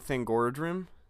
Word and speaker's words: Thangorodrim: But yeah, so the eagles lived in Thangorodrim.